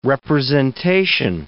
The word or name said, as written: representation